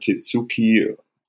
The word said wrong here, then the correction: Tatsuki: Tatsuki Fuyumoto, die Chainsaw Man macht, also das musst ihr ja irgendwann mal.